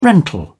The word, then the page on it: rental: British English